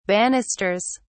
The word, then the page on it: banisters: British English